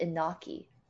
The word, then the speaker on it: Iñaki: I'm sorry, how do you pronounce his name? Iñaki, right? – I would pronounce it Inaki. – Iñaki.